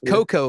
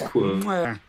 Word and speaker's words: Cuoco: Cuoco or just Coco? Yeah. Like I'm drinking hot cocoa. Is that her? – No, it's Coco? It's Cuoco.